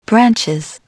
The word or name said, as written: branches